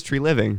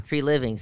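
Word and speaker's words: Treliving: Well, didn't they give how do you say that GM's name again? Treveling. – Speaking of the Lorax and trees and air and living, it's Tree Living. – Tree Living.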